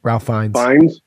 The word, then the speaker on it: Fiennes: But I'm not sure if I'm saying his name correctly, but Ralph Fiennes? – Ralph Fiennes.